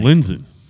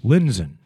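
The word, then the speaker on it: Lindzen: His name is spelled L-I-D-N, L-I-N-D, Z-E-N, I think. – Linzen. – Linzen.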